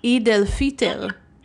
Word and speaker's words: Eid al-Fitr: Okay, so you did mention how it's different to celebrate, oh my God, I need to learn how to say this, Eid al-Fitr.